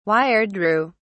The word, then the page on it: wiredrew: British English